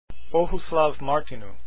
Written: martinu

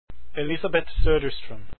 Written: Soderstrom

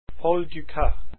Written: dukas

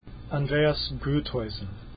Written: groethuysen

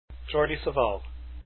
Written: savall

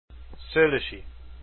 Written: Szollosy

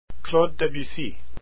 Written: debussy

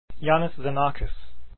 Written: Xenakis

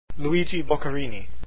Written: boccherini

